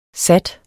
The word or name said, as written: sat